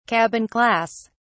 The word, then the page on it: cabin class: British English